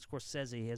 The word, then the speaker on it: Scorsese: The fact that I said Scorsese, as he knows as a film student, like, I'm pretentious alone because that is how you say his name.